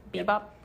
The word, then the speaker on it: Bebop: Bebop. Is that how you say it? – Yeah.